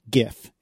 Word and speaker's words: GIF: And there's one exception, of course, and it's pronounced GIF, and that is the only way it should be. And anyone who says otherwise is wrong.